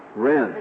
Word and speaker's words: Whren: Is it Whren? Whren. – As if the H isn't there.